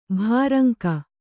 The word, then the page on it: ಭೋರಂಗಿ: bhōrangi